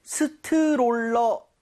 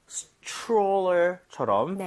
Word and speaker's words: stroller: 자 근데 발음할 때 스트롤러. 아, 이건 좀 아닌 것 같아요. – 아쉽네요. – 이 모양을 좀 많이 모아서 R 사운드 제대로 돼서 약간 치읓 비슷하죠? Stroller처럼 네. – 살짝 변하게 여러분들 이 모양 모으시고 혀 많이 당겨주세요. 자, 발음 갈게요. Stroller.